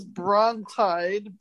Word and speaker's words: brontide: This one is brontide, B-R-O-N-T-I-D-E. So it's spelled simply B-R-O-N-T-I-D-E, brontide.